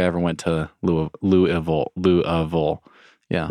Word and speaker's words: Louisville: So yeah, Louisville, you just kind of got to swallow it all.